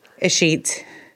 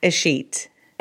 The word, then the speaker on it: escheat: Escheat. – Can you say it one more time? – Escheat. – Escheat. – So, like, my Twitter is don't give Escheat. – Escheat, by the way, E-S-C-H-E-A-T.